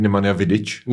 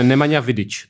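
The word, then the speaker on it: Nemanja Vidić: říct, že od naší uh, pravidelné hostky a kamarádky Magdy Fajtové mám vyřídit, že uh, je špatně to, jak vyslovují jméno toho balkánského fotbalisty. Nemanja Vidič? Jo, jo, jo. Má být Nemanja Vidič? Něco takového, no.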